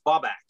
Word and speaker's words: Babak: My given name is Babak. It's spelled B-A-B-A-K, which is not phonetically spelled because it's pronounced Bob and back, like Bob back.